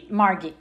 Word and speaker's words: Margie: Margie.